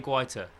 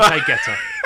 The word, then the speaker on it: Guaita: So Vicente Guaita. – Guaita. – It's fine. It's a good English Guaita. Way of doing it.